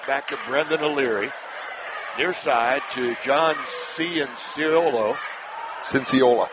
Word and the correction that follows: Cinciola: Cinciola, my mistake. Accent in the wrong syllable.